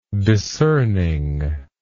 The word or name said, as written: discerning